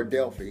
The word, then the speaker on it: Delphi: Now, Delphi, or Delphi, ever how you want to pronounce it, is a platform that runs on Windows that you have one set of source code.